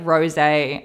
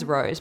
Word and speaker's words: Rosé: So like Rosé, I thought her name was Rose, but she pronounced it Rosé on the thing.